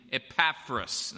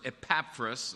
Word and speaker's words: Epaphras: And I'm talking about Paul's reference to a man named Epaphras.